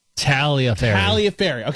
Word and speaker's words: Tagliaferri: Don't pronounce the G. Tagliaferri.